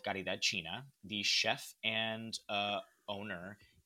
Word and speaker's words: Caridad: Caridad. C-A-R-I-D-A-D.